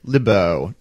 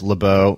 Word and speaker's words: Lebow: Fred Lebow. Now, we're going to probably say his name Lebo and Lebeau both ways. I've heard it literally dozens of times in both ways.